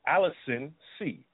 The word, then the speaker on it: Allicin C: The product I'm talking about is called Allicin C, spelled A-L-L-I-C-I-N-C. Let me spell it again for you. Allicin C, A-L-L-I-C-I-N-C.